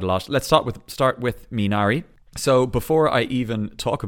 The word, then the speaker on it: Minari: Should be like Minari. Minari.